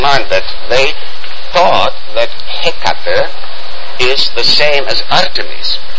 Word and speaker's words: Hecate: I don't know how you pronounce it in English, but... – Hecate. – Yeah, well, the stress is on in the beginning.